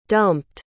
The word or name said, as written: dumped